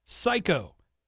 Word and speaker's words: psycho: All you have to do is text me the word psycho. P-S-Y-C-H-O. Of course, Kenzie, you're going to load in a word with a silent letter. Psycho. P-S-Y-C-H-O.